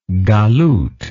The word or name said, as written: galuth